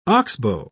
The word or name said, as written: oxbow